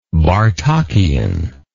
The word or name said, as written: Bartokian